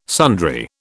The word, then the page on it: sundry: British English